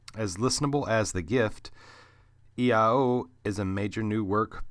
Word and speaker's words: IAO: I've read a little bit about EIO, and it says that it looks like the letters I-A-O, but it's pronounced EIO.